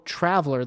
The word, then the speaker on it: traveler: So even though traveler, the, the stress is on the first syllable, it's placed in a slot of the dumb, the dumb.